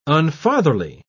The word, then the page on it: unfatherly: British English